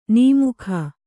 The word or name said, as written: ನಮಕ